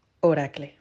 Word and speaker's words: Oracle: Yo también trabajo en Oracle y lo digo así. Oracle.